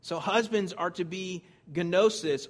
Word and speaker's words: gnosis: It's gnosis, gnosis. The G is silent, though. You can say it if you want, though. Gnosis.